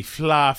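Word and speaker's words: Fleadh: We went into the, we had tickets to the FLA Festival. It was spelled like Fleed, F-L-E-A-D, but it was Irish, whatever.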